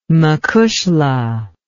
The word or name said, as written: macushla